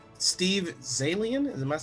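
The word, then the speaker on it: Zaillian: Steve Zalian, am I pronouncing that right? – Yeah.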